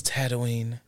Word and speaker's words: Tatooine: Yes, it's Tatooine. – Oh, I said it right the first time.